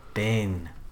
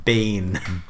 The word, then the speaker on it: Bean: Bin. – Pronounce his name again. – Bean.